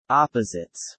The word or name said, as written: opposites